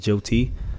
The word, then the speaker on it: Jyoti: On Facebook, I am spelled J-Y-O-T-I.